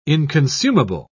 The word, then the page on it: inconsumable: British English